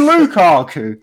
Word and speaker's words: Lukaku: But we've got Mukaku, that lad up top. – Who's Mukaku? – Who the fuck's Lukaku? Do you mean Lukaku? – I don't know.